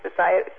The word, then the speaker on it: satiety: How do you say that word? S-A-T-I-E-T-Y. – Satiety.